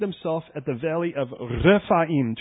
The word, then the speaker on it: Rephaim: Because most of you know how to roll your R's, for instance. Rephaim.